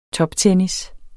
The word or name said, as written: toptennis